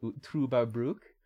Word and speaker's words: Trüberbrook: Uh, it's pronounced with an Uber as in Ubermensch, Trüberbrook.